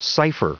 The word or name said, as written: cipher